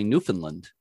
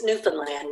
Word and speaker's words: Newfoundland: And I know a lot of Americans tend to say Newfoundland, but Newfoundland. I've been corrected before. Yeah, that it's Newfoundland. – Mm-hmm. Yeah, just Newfoundland.